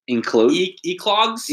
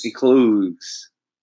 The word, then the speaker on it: Eclogues: Enclogues? – Eclogues? – Eclogues.